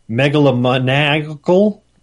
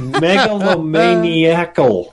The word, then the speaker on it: megalomaniacal: Crime Lord ascends to power and becomes megalomaniacal while a maverick police detective files to stop him. – Wow. – Pronounce that word. Not megalomaniac. – Wow! – But megalomaniacal.